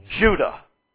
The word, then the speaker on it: Judah: You're used to pronouncing it Judah. Do you see the connection between Jew and Judah? The first syllable of the one and the only syllable of the other sound exactly alike.